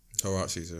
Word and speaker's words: huaraches: Is that how you pronounce that? – Huaraches, yeah.